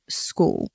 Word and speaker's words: Skool: a system called School. It's spelled S-K-O-O-L.